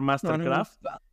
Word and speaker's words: MSTRKRFT: Pues mira, uno que tuvo que haber estado al principio de tu playlist junto con Subtrack debería ser Mastercraft, que en realidad es MSTRKRFT. Es Mastercraft. Que tiene Dance, D-A-N-C-E, Remix, también un rolón.